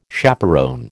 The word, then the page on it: chaperon: American English